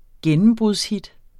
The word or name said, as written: gennembrudshit